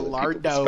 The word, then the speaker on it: Gallardo: I said that in like American English. So Gallardo.